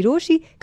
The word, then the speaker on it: Rochie: मेरा नाम है मिर्ची रोशी, काम है आपसे प्यार करना, नाम की स्पेलिंग है ROCHIE.